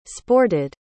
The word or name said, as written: sported